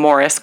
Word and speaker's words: Maurice: I guess first they get this help from this guy named Morris Gross, but it's spelled like Maurice.